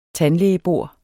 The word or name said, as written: tandlægebor